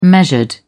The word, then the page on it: measured: British English